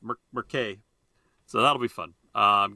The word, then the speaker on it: Murchadh: Because Merkay is spelled in the Gaelic way, Gallic way, M U R C H A D H.